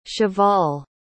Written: cheval

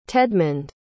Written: tedmund

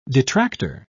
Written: detractor

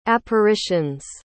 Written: apparitions

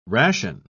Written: ration

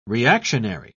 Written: reactionary

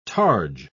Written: targe

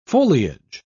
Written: foliage

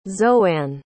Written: zoanne

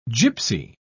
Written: gypsy

